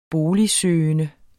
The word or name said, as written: boligsøgende